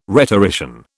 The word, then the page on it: rhetorician: British English